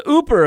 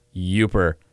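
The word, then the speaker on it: Yooper: You said Ooper a few episodes ago when it's pronounced Uper.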